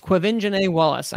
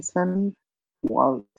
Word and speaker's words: Quvenzhané Wallace: It's Quvenzhané Wallace. I know that one. – Quvenzhané Wallace.